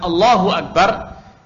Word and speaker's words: Allahu Akbar: Ya, ketika kita mengatakan Allahu Akbar, yang dipanjangkan itu lamnya, Allahu Akbar. Tapi kalau yang dipanjangkan itu hamzahnya, hamzatul wasalnya, sehingga menimbulkan kesan ada hamzatul qata', Allahu Akbar, maka ini merusak arti.